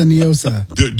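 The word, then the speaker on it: NIOSA: Do not, because it's definitely Nyosa. It is not a Spanish word. What does Nyosa mean in Spanish? Nothing. – So, I don't know. – Well, that's the whole argument. They try to pronounce it like it's Spanish. It's not. It's an acronym.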